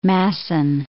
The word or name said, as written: Masson